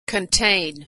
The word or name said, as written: contain